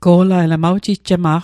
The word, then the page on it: go'lail: Mi'gmaq